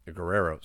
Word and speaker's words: Guerrero: Hector Guerrera. Uh, Guerrero, sorry, I said that wrong.